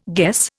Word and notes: guess: Indian

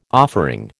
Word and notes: offering: American English

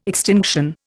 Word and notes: extinction: Indian

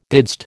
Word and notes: didst: American English

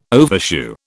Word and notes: overshoe: British English

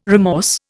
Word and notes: remorse: Indian

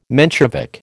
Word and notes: Menshevik: American English

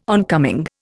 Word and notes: oncoming: Indian

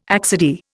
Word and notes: accidie: Indian

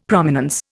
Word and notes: Prominence: Indian

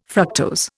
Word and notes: fructose: Indian

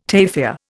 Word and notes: tafia: Indian

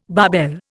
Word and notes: barbell: Indian

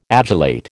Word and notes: adulate: American English

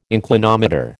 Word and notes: inclinometer: American English

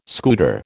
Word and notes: scooter: American English

